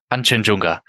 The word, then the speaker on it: Kangchenjunga: Ihr habt ihn ja angesprochen als euer Ziel auf dieser Reise, den Kangenjunga, spricht das richtig aus? Kanchenjunga. Kanchenjunga, okay.